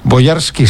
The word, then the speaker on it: Bojarski: Mira, a mí esta película me apetece un montón, porque la historia de Jan Bojarski es una historia, Bojarski, sí, lo he dicho bien, es un apellido polaco, es una historia que siempre me ha gustado.